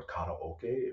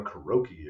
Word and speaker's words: karaoke: But right next door in one of those like collapsible wall things, so you could hear through it, they were doing karaoke or karaoke, if you pronounce it correctly, or a karaoke if you're a goddamn gremlin, because I heard somebody pronounce it like that.